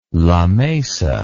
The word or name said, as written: lamesa